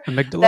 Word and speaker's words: amygdala: I mean, I'm not not necessarily in the how do you pronounce it? Amyg, amyg that that, that little lizard yeah. brain back there. – Amygdala. – That thing, the amygdala.